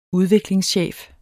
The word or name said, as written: udviklingschef